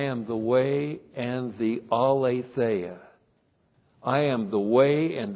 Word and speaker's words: aletheia: Aletheia. A-L, long E-T-H-E-I-A. Aletheia.